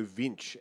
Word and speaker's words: Winch: And that is The Heir Apparent, Largo Winch, or Largo Vinch, as they pronounce it in the film, because it is European, and they pronounce their W's as V's.